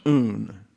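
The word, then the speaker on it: un (οὖν): There's a diphthong here, O-U, pronounced un.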